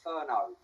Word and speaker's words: Furneaux: Before we go any further, pronunciation of my name, Furneaux.